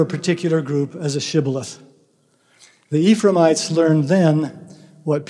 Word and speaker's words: Shibboleth: If they answered no, the Gileadites would ask them to say the word Shibboleth, a Hebrew word meaning stream. Ephraimites would pronounce the word with an S sound, Sibboleth, because they had difficulty pronouncing the SH sound.